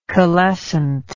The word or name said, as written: calescence